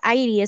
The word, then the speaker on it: Irie: que se escribe Irie y se pronuncia Airi, es una palabra rastafari de Jamaica.